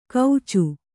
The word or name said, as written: ಕೌಚು